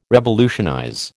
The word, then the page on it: revolutionize: American English